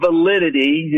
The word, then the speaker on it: Validide: Yeah, so the company is called Validide. It's spelled V-A-L-I-D-I-D-E, but it's pronounced like the word validity.